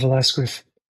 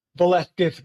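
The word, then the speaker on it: Velázquez: I know the Spanish would say Velasquez, but I just can't bring myself to say that.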